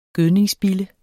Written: gødningsbille

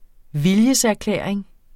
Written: viljeserklæring

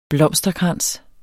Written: blomsterkrans

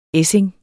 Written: ESSING

